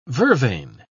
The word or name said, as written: vervain